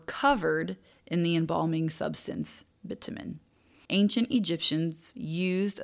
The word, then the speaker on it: bitumen: In the 12th century, Europeans were in North Africa for the Crusades, and they learned about, I think it's pronounced bitumen. Bitumen. It's B-I-T-U-M-E-N.